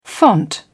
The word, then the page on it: font: British English